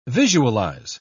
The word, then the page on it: visualise: British English